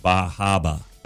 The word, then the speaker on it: Bar Harbor: I don't mean to be mansplaining, but you realize it's pronounced Bahaba, right? – Thank you.